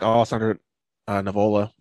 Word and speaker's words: Alessandro Nivola: So, yeah, I did like Alessandro Nivola. Did I say it right? – I think so.